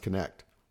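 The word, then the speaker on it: Knecht: I think it's pronounced Connect. That'd be really easy to have fun with that, right? – That'd be great.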